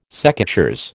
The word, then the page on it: secateurs: American English